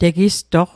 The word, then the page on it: tegistoq: Mi'gmaq